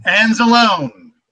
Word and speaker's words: Anzalone: Anzalone.